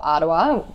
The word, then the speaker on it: Aroa: Now, we see there's a really strong market opportunity for Arawa, which is the New Zealand pronunciation of the name.